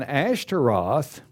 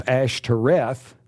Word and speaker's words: Ashtaroth: And Ashtaroth, it can be pronounced in various cultures, Ashtoreth or Astarte, but Ashtaroth is a Phoenician goddess of love and increase, and that occurs 11 times in the Old Testament.